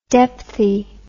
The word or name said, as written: depthie